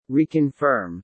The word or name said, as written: Reconfirm